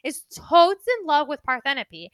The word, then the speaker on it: Parthenope: A woman named Parthenope. – Parthenope, thank you.